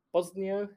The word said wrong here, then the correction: Bolsonaro: how do you say his name? – Bolsonaro. Bosnia being, is a country.